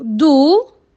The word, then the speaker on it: dos: Au dos, le O s'écrit A-U. Et le mot dos se termine par S, je le vois mais je l'entends pas. Mal, mal.